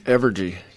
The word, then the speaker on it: Evergy: Evergy. – Evergy.